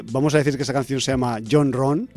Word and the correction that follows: Honron: Se llama Honron. – Honron, perdón. Honron. O sea, lo he dicho mal en directo. – Lo digo en directo, pero igual vale la pena contar que es como home run, que es cuando hace uno está jugando béisbol y le da tan duro a la bola que la bola sale fuera del campo.